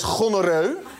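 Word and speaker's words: gonorroe: Gonorrhea is gonorreu.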